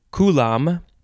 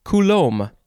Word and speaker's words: coulomb: Okay, so the first word in this episode is, you can pronounce it coulomb or coulomb. The first syllable is emphasized. The second syllable is either lom or loam. C-O-U-L-O-M-B.